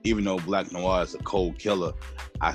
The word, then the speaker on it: Black Noir: I did do my research, though, on, I'm not sure if I'm saying his name right, Black Noir. Yes.